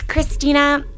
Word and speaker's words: Kristina: It's Kristina, KristinaLibby. And my last name is Libby, L-I-B, as in boy, B-Y, .com.